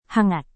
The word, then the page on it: hangat: Indonesian